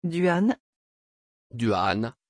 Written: Duane

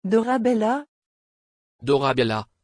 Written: Dorabella

